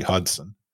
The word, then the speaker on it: Hodgson: Like Cody Hodgson was Cody Hodgson forever until one day he was Cody Hudson, which is apparently the way his name would be pronounced.